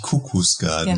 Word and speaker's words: Kukus Garden: Das ist jetzt schon öfters passiert, dass äh, das äh, Kuckucksgarden genannt wird. – Aber es ist tatsächlich Kuckucksgarden. – Genau, Kuckucksgarden.